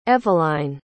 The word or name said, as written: evelyne